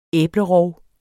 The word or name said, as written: æblerov